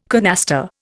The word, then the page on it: canaster: Indian